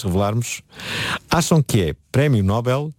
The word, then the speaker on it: Nobel: Nós quando pronunciamos, por exemplo, papel e anel, nós pomos a sílaba tónica na, na segunda sílaba. Nobel. Nobel, Noel. E Nobel sim. – É exatamente a mesma coisa.